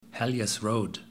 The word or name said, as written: Hellyers Road